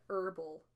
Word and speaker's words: herbal: You don't pronounce the H in herbal.